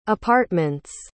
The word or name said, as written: apartments